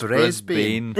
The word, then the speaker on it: Brisbane: It's Brisbane.